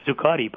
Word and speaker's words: Zuccotti: It began in September 2011 in Zuccotti Park, if I'm pronouncing it right, in New York. – Yes. – Zuccotti Park, I guess it is, in New York, near Wall Street.